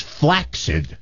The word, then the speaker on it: flaccid: The preferred pronunciation is flaccid, like F-L-A-K-S-I-D.